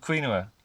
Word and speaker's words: quinoa: Same with the quinoa. – Yes. Quinoa. That's right. – Oh shit! I said it wrong again.